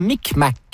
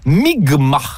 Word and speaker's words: Mi'kmaq: Micmac, qui se prononce en fait Migma, dans ce qui sera le premier contact.